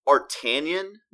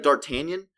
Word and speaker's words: D'Artagnan: It's D apostrophe Artanian, but the D is silent. But when you read it, it looks like D'Artagnan just like they do in Stranger Things 2, pisses me off.